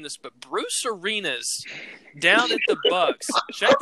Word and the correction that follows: Arians: Bruce Arenas? – Arians. – Arians.